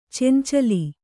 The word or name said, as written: ಚೆಂಚಲಿ